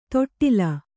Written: ತೊಟ್ಟಿಲ್

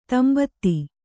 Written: ತಂಬತ್ತಿ

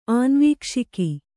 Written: ಆನ್ವೀಕ್ಷಿಕಿ

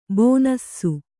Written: ಬನಸಿ

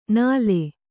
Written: ನಾಲೆ

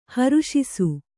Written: ಹೊರಸೂಸು

